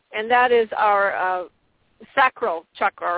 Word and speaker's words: chakra: I do know the Sanskrit alphabet, and I do know that it's pronounced chakra.